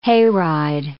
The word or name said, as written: hayride